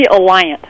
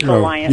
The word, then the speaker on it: Alliant: And I'm joined on the phone by a forensic psychologist professor at Elliott University in San Francisco. Hey, am I pronouncing that university correctly? – It's actually Alliant. I didn't want to say anything, but it's Alliant.